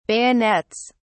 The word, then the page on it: bayonets: British English